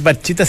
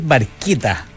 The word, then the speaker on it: Barchetta: Y no es... Red Barchetta, sino que es Red Barchetta. – Se escribe Barchetta, pero según se escribe en italiano.